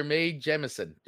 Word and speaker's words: Mae Jemison: Tell us about Dr. Mae Jemison.